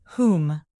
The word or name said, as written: whom